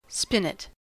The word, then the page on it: spinet: American English